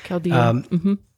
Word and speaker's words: Chaldean: And even when he did the mass the other day, Chaldean, right? I think that's how you say it. First time a Pope's done that one. – Yeah, Chaldean.